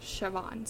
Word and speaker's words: Siobhan: If my mom had named me Siobhan, my name would have been spelled S-I-O-B-A-H-N. And that is pronounced Siobhan.